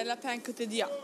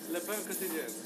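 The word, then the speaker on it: Le Pain Quotidien: Ja, Le Pen Quotidien. – Okej. – Okay. Oj. Rugby. – Hi. We just have a question. How do you pronounce the name of this place? – Le Pen Quotidien.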